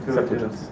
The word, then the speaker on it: Septuagint: Like Chazal can tell me that they translated the The Septuagint, how do you pronounce that? – Septuagint. – Something like that, right.